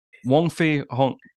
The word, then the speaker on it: Wong Fei Hung: So Jackie Chan's character, I'm sorry if I mispronounced, it's Wong Fei Hung.